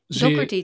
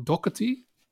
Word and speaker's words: Doherty: Doherty, toch? – Oh, Doherty? – Volgens mij spreek je dat als Doherty, maar dat weet ik niet. Oké. – Okay. Oké, dat verschilt misschien. – Dat moet jij weten. – Ik dacht Doherty.